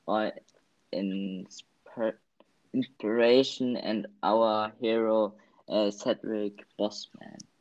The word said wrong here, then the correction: Boseman: Boseman. Aussprache immer noch, ne? – Boseman, sorry.